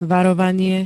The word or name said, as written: varovanie